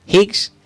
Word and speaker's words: Higgs: ¿Lo estoy diciendo bien? ¿Higgs? ¿Cómo se dice? – Higgs. – ¿Higgs? – Higgs. – Bosón de Higgs.